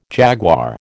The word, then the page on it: jaguar: American English